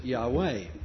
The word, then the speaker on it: Yahweh: And the modern writers don't supply the vowels, but they tell us to pronounce it Yahweh.